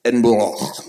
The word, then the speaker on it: Edinburgh: Wait, you didn't say that right. It's Edinburgh.